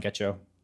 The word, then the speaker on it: Getcho: And then yeah, like so they're at Get Cho Podcast. It's the way it sounds. It's spelled the way it sounds. G E T C H O podcast.